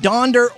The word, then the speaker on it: Donder: Donder rhymes with yonder, which is over where you can find someone who gives a flip.